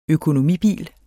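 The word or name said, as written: Økonomibil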